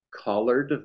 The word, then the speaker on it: Collard: But it's pronounced Sneed B, middle initial, collard, like collard greens, if you've ever heard of those, and the third, which means that my father and grandfather were both named Sneed Beak.